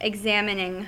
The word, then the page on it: examining: American English